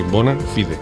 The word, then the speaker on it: Bona Fide: Burn Me, More Be Nights, de la banda norteamericana Bona Fide, que como es latín, pues se pronuncia así, Bona Fide.